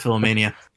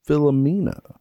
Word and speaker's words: Philomena: I'll always pronounce it Philomania. – I'm going to go with Philomena. – I'm going to follow suit. I like Steve Coogan, and I will, for that reason, choose Philomena.